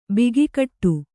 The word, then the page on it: ಬಗೆಗಿಡು: bagegiḍu